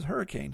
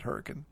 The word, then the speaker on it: Hurricane: It's spelled Hurricane, but anybody that comes in the area and says, oh, this is Hurricane, huh? Then we know you're not from around here because everybody pronounces it Hurricane.